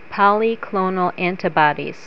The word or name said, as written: polyclonal antibodies